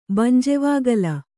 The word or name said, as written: ಬಂಜೆವಾಗಲ್